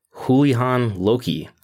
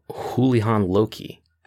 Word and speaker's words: Houlihan Lokey: Speaking of the XFL, according to Axios reported last week Thursday that investment bank Houlihan Lokey, I'm pronouncing that right, Houlihan Lokey, not to be confused with the former Ring of Honor world champion, is managing the process to sell the XFL.